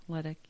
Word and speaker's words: Ledecky: Ledecky.